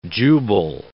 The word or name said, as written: Jubal